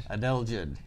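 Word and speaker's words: adelgid: Uh, Adele. Think of the singer Adele. Adele Jid, yes.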